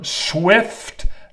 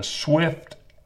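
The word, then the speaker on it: Zwift: And as I understand it, you place your bike on the machine, the Wahoo Kicker Smart Trainer, and she connects it to her Swift app, and it's spelt with a Z, the Swift app,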